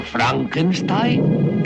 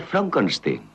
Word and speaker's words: Frankenstein: ¿Doctor Frankenstein? Frankenstein. ¿Me toma el pelo? No, se pronuncia Frankenstein.